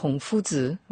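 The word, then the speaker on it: Fuzi (夫子): Alors je vous laisse le prononcer en chinois. – Fuzi.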